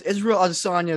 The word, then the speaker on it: Israel Adesanya: You gotta say it like this. Israel Adesanya is one of the greatest middleweight champions right now.